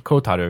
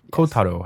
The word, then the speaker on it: Kotaro: Uchikoshi Kotaru. Yes. Kotaro.